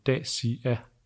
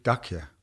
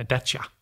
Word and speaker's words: Dacia: Og vi holder jo selvfølgelig fast i at udtale navnet Dacia. – Ikke Dacia. – Nej, Dacia.